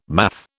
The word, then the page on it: math: British English